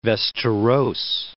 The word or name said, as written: Vasteras